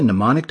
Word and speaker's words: mnemonic: A mnemonic device will make this even easier. A what device? Mnemonic, the first M in the word is silent, came from a Greek word meaning to remember.